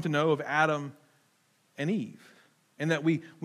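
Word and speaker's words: Adam (אָדָם): And it's interesting that the Hebrew word for humanity is this word that is pronounced Adam.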